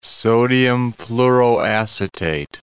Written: sodium fluoroacetate

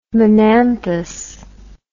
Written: monanthous

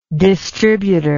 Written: distributer

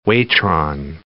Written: waitron

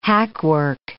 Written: hackwork

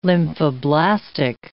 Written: lymphoblastic

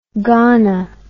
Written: gana

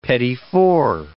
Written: petit four

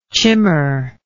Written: chimar

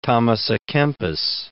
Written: Thomas a Kempis

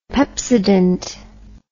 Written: pepsodent

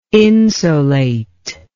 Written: insolate

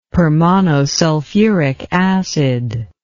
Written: permonosulfuric acid

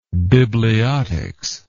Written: bibliotics

